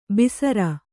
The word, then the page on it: ಬಿಸರ: bisara